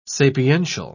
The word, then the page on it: sapiential: British English